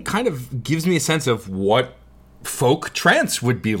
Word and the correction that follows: folktronica: It's pronounced folktronica. – Oh, folktronica. Excuse me.